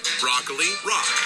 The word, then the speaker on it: broccoli rabe: It's pronounced broccoli rob.